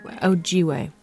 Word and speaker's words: Ojibwe: Yes, Ojibwe.